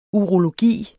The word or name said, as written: urologi